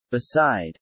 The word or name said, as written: beside